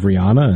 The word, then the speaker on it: Rihanna: Have we changed the pronunciation it's Rihanna.